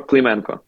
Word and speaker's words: Klymenko: Oh, my name is Igor Klymenko.